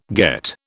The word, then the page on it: get: British English